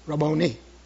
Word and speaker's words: Rabboni: And he said, "Well, first of all, you mispronounce it." Well, I'm not a Greek. I don't know Greek or Hebrew. But he said, it's Rabboni. Rabboni. The I is pronounced like an E.